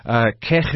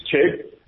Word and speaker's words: Chegg: we do pronounce the J as a K, but it's Chegg.